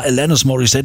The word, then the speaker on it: Alanis Morissette: Bis dann irgendwann hieß, ja, Alanis Morissette, die kommt aus Kanada, englische Aussprache und so.